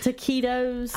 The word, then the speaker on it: taquitos: Taquitos? – Uh-huh. – Is that how you say it? – Yes.